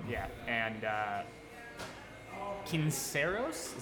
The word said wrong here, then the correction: Cisneros: Cisneros? – There you go.